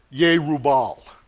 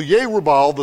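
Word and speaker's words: Jerubbaal: Ancient Hebrew had no J sound in it. So if that happens, that's what's going on. And don't think that, you know, I'm lost my mind or I don't know how to read. I just try to pronounce it in the way the Hebrew language is pronounced, like Yerubal. There's no J sound, so that's how we would properly pronounce that name.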